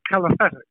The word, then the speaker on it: calisthenics: And they're teaching kill sprint and calisthenics.